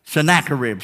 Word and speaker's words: Sennacherib: There was a king by the, by the name of Zeracharib, Sennacherib, Sennacherib.